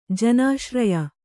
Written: ಜನಾಶ್ರಯ

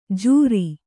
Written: ಝೂರಿ

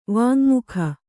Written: ವಾಙ್ಮುಖ

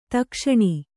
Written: ದಕ್ಷಿಣ

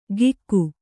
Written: ಕಿಕ್ಕು

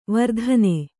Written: ವರ್ತನ

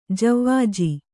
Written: ಜವಾಜಿ

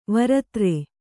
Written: ವರುತ್ರ

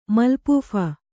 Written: ಮಲ್ಪೂಫ

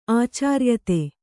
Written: ಆಚಾರ್ಯತೆ

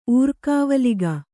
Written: ಊರ್ಕಾವಲಿಗ

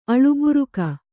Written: ಅಳುಬುರುಕ

